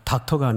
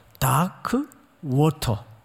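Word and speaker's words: Dark Waters: 그 기독교 윤리 실천운동 본부에서 소개해 준 영화인데 닥터가 아니라 다크 워터. 영국식 발음입니다. 영국식.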